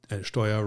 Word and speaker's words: Steuer: And Steuer rhymes with teuer, which is means expensive.